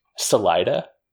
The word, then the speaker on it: Salida: What's it called? – Salida. – How the hell do you spell that? – S-A-L-I-D-A.